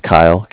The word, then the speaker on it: Kyle: It's Kyle, K-Y-L-E.